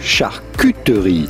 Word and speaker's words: charcuterie: Because yes, it is a French word and a name of French origin, and the French pronounce the U, U, charcuterie.